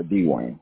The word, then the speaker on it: DeWayne: Dwayne.